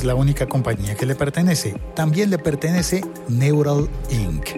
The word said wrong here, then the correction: Neuralink: No, perdón, lo dije mal. Neuralink.